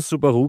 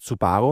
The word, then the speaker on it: Subaru: Ja, ich sag ehrlich, also ich habe immer Subaru gesagt und dann habe ich mit irgendwelchen Leuten mal geredet, die haben immer gesagt Subaru und dann dachte, wer bin ich jetzt, der Trottel? Habe ich falsch gesagt in mein ganzes Leben.